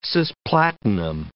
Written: cis-platinum

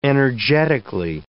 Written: energetically

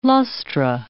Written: lustra

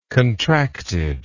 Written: contracted

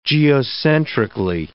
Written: geocentrically

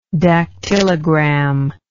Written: dactylogram

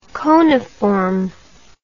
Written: coniform

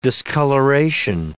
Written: discoloration

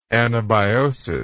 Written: anabiosis

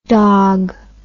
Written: dawg

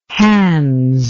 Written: Hans

